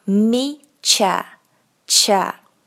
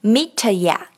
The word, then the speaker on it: meet you: 你已经听到我刚刚念了 me cha cha，而不是念成 meet ya。 No，t 和半元音的 ya 组成了一个新的音 ch ch。